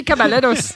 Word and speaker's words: Caballeros: Did you just have a Spanish accent? Three Caballeros. – Well, that's how you say it.